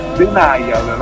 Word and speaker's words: Denial: Io subito un appunto, noi siamo i Daniel. – Come? – Denial, la pronuncia corretta del nome è Denial.